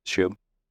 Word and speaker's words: eschew: Did I pronounce that correctly? – Yeah, eschew.